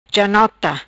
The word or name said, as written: Janota